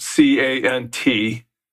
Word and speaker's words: cant: C A N T is a, is a cant. – A cant. Okay.